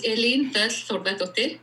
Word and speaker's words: Elin Thordardottir: En Islande, ça se prononce Elin Thordardottir.